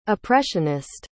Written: oppressionist

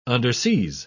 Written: underseas

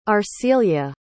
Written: arcelia

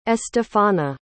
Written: estefana

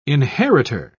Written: inheritor

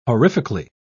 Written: horrifically

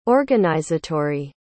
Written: organizatory

